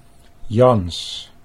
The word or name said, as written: Jans